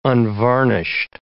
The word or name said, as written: unvarnished